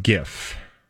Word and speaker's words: GIF: Or Jiffy Pop popcorn. – Ooh. – Right. Yeah, there's lots of Jiffies in pop culture, but it's not on the peanut butter. Okay. – Also, it's pronounced GIF.